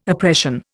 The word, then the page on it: oppression: Indian